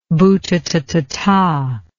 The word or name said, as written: bhutatathata